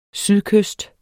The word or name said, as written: sydkyst